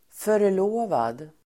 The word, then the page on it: förlovad: för_l'å:vad